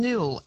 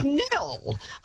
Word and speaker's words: Nhill: But look, Kate, also, our town, Nill, N-H-I-L-L, and I get so much, I've just been to Melbourne and, you know, checked into hotels. They cannot pronounce the silent. They say N-Hill. Excuse me. It is Nill.